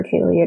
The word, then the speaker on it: Kaely: My name is spelled weird for Kaely. It's K-A-E-L-Y.